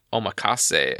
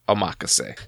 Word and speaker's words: omakase: I used to pronounce that omakase, and then I realized I was totally wrong. Oh, so it literally means I'll leave it up to you, omakase.